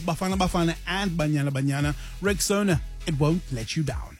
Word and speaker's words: Rexona: Now, if you haven't heard, Shield is now Rexona, a brand you've trusted for years, is stepping forward with a bold new name pronounced Rexona. Simply strong and easy to remember.